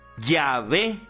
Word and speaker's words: Yahvé: Lo mismo, J, W, H, W es Yahvé, científicamente, no Jehová. Jehová está mal pronunciado. Los evangélicos cogieron eso para diferenciarse, pero cuando tú dices Jehová, es como si estuviera diciendo mi house is beautiful, le está pronunciando mal. Científicamente es Yahvé, porque los hebreos no tienen vocales, sino solo consonantes.